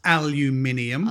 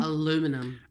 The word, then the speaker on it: aluminium: For all the American listeners, it's aluminium. – Aluminum.